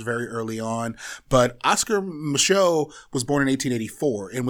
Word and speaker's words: Micheaux: Am I butchering his last name? Is it Micheaux? – That's correct.